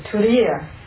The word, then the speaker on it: Turiya: The Turiya. Turiya means T-U-R, long I-Y-A.